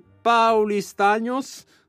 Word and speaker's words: paulistanos: Gli abitanti di San Paolo sono chiamati paulistanos. Che è giusto? – Sì. – Ho detto bene, allora.